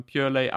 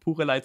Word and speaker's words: Purelei: Auf jeden Fall sagt man Purelei, aber es ist auf jeden Fall auch erlaubt, Purelei zu sagen. Ganz normale deutsche Aussprache, weil es ja auch eine in Deutschland gegründete Brand ist.